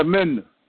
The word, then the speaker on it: mina: And, okay, that's how you say it? Minna. M-I-N-A? – Yeah, Minna.